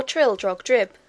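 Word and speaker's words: Trildrogdrib: It contained a warrant for conducting me and my retinue to Trail-Drag-Dub, or Trill-Drog-Drib, for it is pronounced both ways as near as I can remember, by a party of 10 horse.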